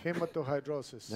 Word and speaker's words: hematohidrosis: Hematohidrosis. – Ese es el nombre. Hematohidrosis.